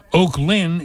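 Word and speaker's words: Oaklyn: Oak Lynn is in Camden County, and it's O-A-K-L-Y-N. Not like Oakland. There's a town in North Jersey called Oakland. – Oh, but you put the emphasis on the second.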